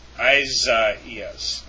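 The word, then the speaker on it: Isaias (Ἠσαΐας): Hazaias, or the Greek form for Isaiah, is pronounced with four syllables. Hazaias. Isaias.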